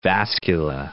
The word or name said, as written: vascula